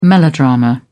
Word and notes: melodrama: British English

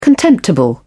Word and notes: contemptible: British English

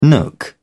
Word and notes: nook: British English

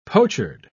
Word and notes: pochard: British English

